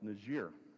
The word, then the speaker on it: Niger: And so we get its name actually from the French, meaning black, and it is pronounced Niger.